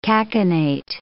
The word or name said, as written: cachinnate